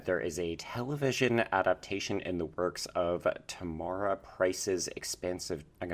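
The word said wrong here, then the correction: Tamora Pierce: Okay, I'm going to rewind you a bit. Tamara Pierce. – Yeah. Did I say Tormora? – Yes, you did. Said Tormora Price, not her name.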